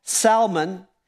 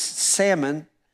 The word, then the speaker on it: salmon: And they sailed under the shelter of Crete off Salmon. And that's some of you guys that pronounce the fish wrong. This, it's salmon.